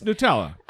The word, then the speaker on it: Nutella: And also, I'm probably pronouncing that wrong. That's how I say it. – It's Nutella.